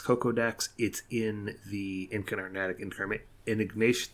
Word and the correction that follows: Enigmatic Incarnation: Why do I always mispronounce that card? Enigmatic Incarnation deck.